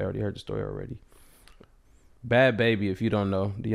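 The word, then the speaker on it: Bhad Bhabie: is said bad baby. That's what they call her. I know that's how it's spelled, but they call her bad baby.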